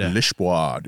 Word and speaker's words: Lisboa: Lisboa, dude. Portugal, dude. – And the renowned Lisboa Bend. – Is that how you pronounce it? – Mm-hmm.